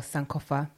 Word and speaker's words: Sankofa: If you don't know what Sankofa is, or Sankofa, I don't know if I'm pronouncing it right. – You are.